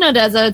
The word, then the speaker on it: Odesza: about Odessa really quick.